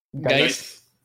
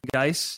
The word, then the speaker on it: Guice: After what happened to, I'm not going to say his name right, to Guice. – Guice. – Guice, yeah, after what happened to Guice, I think everyone's in full panic bubble wrap mode for all of their favorite players.